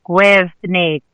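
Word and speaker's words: Gwyrddni: That's spelled G-W-Y-R-D-D, capital N-I. Gwyrni. – You're putting an extra syllable in that. It's Gwyrni. – The double D in Welsh is pronounced the, as in there, or then, and then everything else is more or less how it looks and how it sounds.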